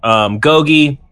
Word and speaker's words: Gogi: Gogi, it rhymes with the Tagalog word pogi, which means handsome boy in the Filipino language.